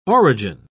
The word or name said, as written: origin